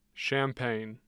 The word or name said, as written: champaign